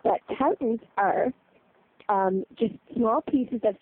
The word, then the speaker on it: toutons: It's spelled T-O-U-T-O-N-S. – Oh, Tautens, yes. – Yes, Tautens.